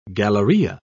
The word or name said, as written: galleria